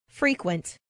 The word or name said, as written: frequent